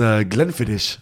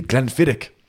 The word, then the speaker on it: Glenfiddich: He said we pronounce Glenfiddich wrong, and it's actually Glenfiddich.